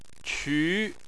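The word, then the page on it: qu: tone 2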